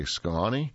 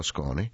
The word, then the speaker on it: Skåne: Well, before we continue, I want to spend a line or two on Skåne, spelled S-K-A-N-E, but pronounced, you can say Skåne or Skåne.